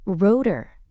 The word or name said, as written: rotor